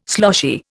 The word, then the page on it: sloshy: Indian